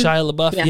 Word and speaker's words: Shia LaBeouf: Shia LaBeouf, yeah.